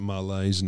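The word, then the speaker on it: Marles: He pronounces it Marlays now.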